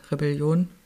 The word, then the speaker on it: Rebellion: Rebellion Tour von Tobias Beck in den Abend, beziehungsweise Rebellion, es wird glaube ich deutsch ausgesprochen, ja klar, er macht ja Werbung mit dem Reh.